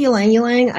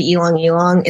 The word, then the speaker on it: ylang ylang: Do you pronounce the L in ylang ylang? – I say ylang ylang. I know some people say elong elong.